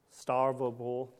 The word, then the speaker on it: Stavropol: It says, so back in the 1930s in, try not to butcher this town, Stavropol, did I say that right? Russia. It was close.